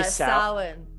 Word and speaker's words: Samhain: I say Samhain.